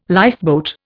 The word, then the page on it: lifeboat: Indian